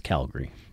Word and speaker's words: Calgary: In case you don't know, it's Calgary.